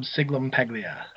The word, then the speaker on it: Sciglimpaglia: Rob Scaglapagula.